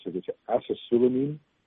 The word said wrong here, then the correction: acesulfame: As, acesulfame.